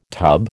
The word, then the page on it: tub: American English